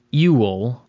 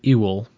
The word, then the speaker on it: Yule: Yule, pronounced Ewell, E-W-E-E-L-L-E, so Ewell, is when the dark half of the year relinquishes to the light half.